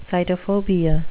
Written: sitophobia

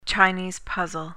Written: chinese puzzle

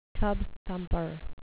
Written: tub-thumper

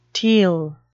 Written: teal

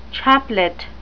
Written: chaplet

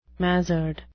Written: mazard

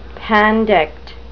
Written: pandect

